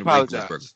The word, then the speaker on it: Roethlisberger: And the only other guy that I could think that's close to it that would not be black is Ben Roethlisberger, but he's a dick. – You mispronounced his name, sir. That's Ben Roethlisberger.